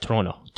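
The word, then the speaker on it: Toronto: Кстати, ребята здесь не произносят Торонто как Торонто. Они говорят Троно. Троно. Троно. Они не произносят вторую Т.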